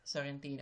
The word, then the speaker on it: Sorrentino: But it is an interesting visual concept that, how do you say it? Sorrentino? Is that how you say the director's name? – Sorrentino, yes.